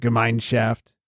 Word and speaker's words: Gemeinschaft: But the mine has a little bit of an emphasis. It's like Gemeinschaft. Apparently, Gemein is separate from shaft.